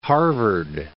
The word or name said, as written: Harvard, Mount